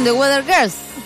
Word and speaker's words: The Weather Girls: y son The Weather Girls.